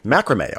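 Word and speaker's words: macramé: I only wear styles of clothing that rhyme with the word okay, okay, macrame, okay.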